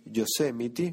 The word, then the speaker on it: Yosemite: Yosemite, cómo cuesta pronunciar eso, pero sí, así se pronuncia.